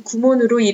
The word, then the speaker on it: 구몬: 일본 구몬수학과 연계의 대교의 전신인 한국공문수학연구회를 창립한 강영중 회장이 일본 구몬수학에서 로열티 인상과 함께 공문 대신 일본식 발음인 구몬으로 이름을 바꾸라고 요구하자 기존의 공문수학 브랜드를 포기하고 브랜드명을 눈높이로 바꾼 것과 달리 교원 구몬은 여전히 일본 브랜드인 구몬을 고집하고 있어 대조적입니다.